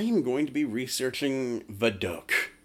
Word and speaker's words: Vidocq: Vidocq. – Yes, Vidocq.